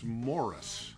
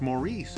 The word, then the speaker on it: Maurice: M-A-U-R-I-C-E. I know people say, oh, wait a minute, that's Maurice, isn't it? – Some people call me Maurice. – Well, this is the Irish M-A-U-R-I-C-E. So they would pronounce it Morris, like Morris. People named Morris in Ireland would often be nicknamed Moss, M-O-S-S.